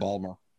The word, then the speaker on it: Baltimore: I don't even think they pronounce the T. I think it's just Baltimore.